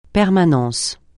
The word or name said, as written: permanence